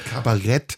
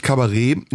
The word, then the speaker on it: Kabarett: Das ausgesprochen Kabarett und nicht Kabarett.